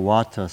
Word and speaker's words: vata: So the V's are actually pronounced as W's. Okay? So it's anicca vata sankara upatava yadamino.